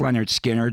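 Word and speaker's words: Lynyrd Skynyrd: That goes back to 1980, in an album called Anytime, Anyplace, Anywhere, and where it all started, the very first Leonard Skinner album, pronounced Leonard Skinner, we heard I Ain't the One.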